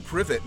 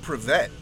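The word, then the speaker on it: Privett: I think it would behoove you to learn the pronunciation of Andrew Privet, not Privet, if you want to criticize our club while wearing our shirt, jackass.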